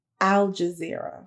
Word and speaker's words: Al Jazeera: Al Jazeera.